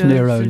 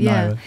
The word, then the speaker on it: Nyro: To remember that it's Nyro, not Nyro.